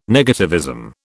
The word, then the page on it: negativism: British English